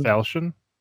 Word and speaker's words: falchion: Is that a falchion? Is that how you say it? – Yep. And just for people who don't know,